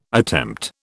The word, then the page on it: attempt: British English